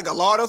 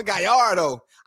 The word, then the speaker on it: Gallardo: You can't even say it right, nigga. It's not a Gallardo, it's a Gallardo.